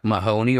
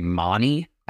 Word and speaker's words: Mahoney: And Jack Mahoney, or as some like to say, Monty. – I think Monty is the standard pronunciation in Australia. They do weird things with A's and H's, I guess. Look at that, the McGrath name as well. – You got that one wrong, Australia. It's Mahoney.